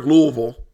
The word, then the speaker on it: Louisville: We were in Louisville.